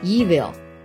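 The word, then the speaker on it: Evian: Evil，这就是依云的法语发音。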